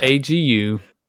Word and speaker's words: Sienna Agudong: How do you pronounce that? – Agudong. – Is it Agudong? – Agudong. – Agudong. – Agudong. Agudong. There you all go.